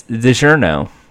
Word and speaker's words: DiGiorno: How do they say it in the commercials? – It's not delivery, it's DiGiorno. Because they want the D-E.